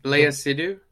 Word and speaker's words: Léa Seydoux: Uh, the is it Léa Seydoux? – Yeah, all right, Léa Seydoux.